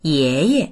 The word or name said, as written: ye2ye